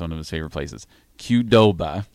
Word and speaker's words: Qdoba: The mini mag includes tons of great discounts from your favorite spots in Lincoln, like Kubota, Alumni Hall. Did I pronounce that wrong? – Qdoba. – Qdoba.